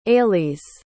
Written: ailise

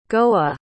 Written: goa

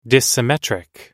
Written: dissymmetric

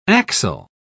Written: axel

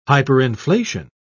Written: hyperinflation

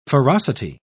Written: ferocity

